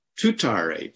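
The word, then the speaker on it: Tuttare: Tutare, T-U-T-T, long A-R-E, T-U-T-T, long A-R-E in Sanskrit.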